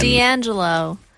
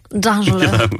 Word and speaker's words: D'Angelo: D'Angelo. – His name's not Dan and last name Jello. – Sorry, I was doing the French pronunciation. – D'Angelo.